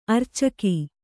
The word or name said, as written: ಅರೋಚಕಿ